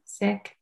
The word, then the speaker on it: Sikh: The correct way to pronounce it is Sikh.